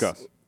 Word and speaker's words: Gus: Gus. – Why don't we say Goose? – It's easier for white people to say Gus. They just say G-U-S.